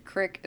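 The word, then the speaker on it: creek: They're creeks, for those of you that are north of Missouri. – Yeah, exactly. Yeah. Crick is the correct pronunciation.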